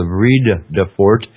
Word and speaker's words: Vredefort: in terms of the Mineral and Petroleum Resources Development Act, granted the application for prospecting rights around the Vredefort Dome, a World Heritage Site, to White Rivers Explorations.